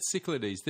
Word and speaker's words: Cyclades: Cyclades. – Cyclades. But he said Cyclades, didn't he? – He did. He said that in French it's Cyclades, but, you know, I've got Greek friends who wanted to murder me for saying that, so.